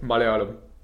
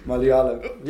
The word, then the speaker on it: Malayalam: Say it again. – Malayalam. – Malayalam. – Yeah, that you actually. – Malayalam. – Yeah, you actually got it right. Kudos. – Cheers. – Kudos. He has decent pronunciation. – Malayalam. – Yeah, that's perfect.